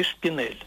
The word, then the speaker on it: шпинель: А в слове «шпинель» ясно чувствуется «э» оборотное.